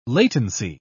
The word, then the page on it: latency: British English